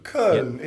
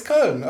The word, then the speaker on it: Köln: And I can only apologise for my pronunciation of that club. But 4-0 over Köln. With a hat-trick. – Very good. Köln. Yep. It's almost perfect. Come on. – Tell him that, you know, when he makes a mistake, tell him. – Right. – No, it's Köln. It's great.